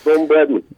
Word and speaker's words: Bradman: Don Bradman.